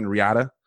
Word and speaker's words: Riyadh: He says that, you know, he was, I believe, present when John Brennan, as the CIA station chief in Riyadh, and I hope I'm pronouncing that right. If you guys want to look it up, it's R-I-Y-A-D-H. I mean, these people, I think you probably got to say like Riyadh or some shit because it's got that H.